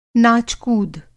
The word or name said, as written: नाच-कूद